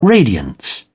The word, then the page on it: radiance: British English